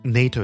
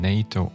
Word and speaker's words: NATO: NATO — это тоже аббревиатура, но в том числе это еще и акроним, потому что NATO не читается отдельно по буквам N-A-T-O, а произносится как одно слово.